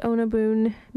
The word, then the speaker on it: Under Byen: It's spelled U-N-D-E-R-B-Y-E-N, pronounced Onabun, means under the city, and it's from their album, which translates as Same Fabric as Fabric.